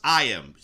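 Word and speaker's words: Iman: Well, before we get there, so this woman it's pronounced Iam.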